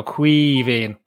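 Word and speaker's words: Caoimhín: Think the best way to imagine how to pronounce it, Neil, is imagine you're half pissed, right, and just go Quivín.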